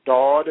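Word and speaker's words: dod (ضاد): And next we have the letter dod again. It's pronounced like the first part of the word daughter.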